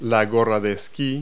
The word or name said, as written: ski hat